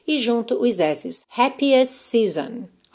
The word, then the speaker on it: season: Nesse season que eu também pintei aqui de verde só porque a escrita não te leva para a pronúncia correta, né? Nós temos S-E-A-S-O-N e a pronúncia é season, season, ok?